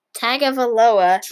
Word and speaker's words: Tagovailoa: Tagovailoa or something like that? – Tagovailoa.